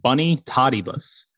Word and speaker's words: Bonitatibus: It's pronounced Bunny Tottibus and it's spelled B-O-N-I-T-A-T-I-B-U-S.